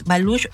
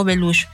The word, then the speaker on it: Baluch: Se escribe Baluch, pero es Baluch o Beluch.